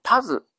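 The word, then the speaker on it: たづ: 葦というのは、この干潟の水が浸っているところに、生えているススキの大きくなったようなものを言いますし、それから古典の世界では鶴という読み方をしないんですね。タズと発音したり、または足タズノと言ったりします。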